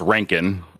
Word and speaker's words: Röntgen: And it's pronounced Röntgen.